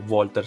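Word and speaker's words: Valter: Miles Scottson e Attila Walter o Walter. La pronuncia dovrebbe essere Walter se non sbaglio.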